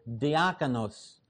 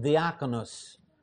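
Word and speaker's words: diakonos (διάκονος): The Greek word behind it, and you will be able to tell easily that it's a borrow word, is diakonos, or in modern pronunciation, diakonos, but you can hear deacon in there.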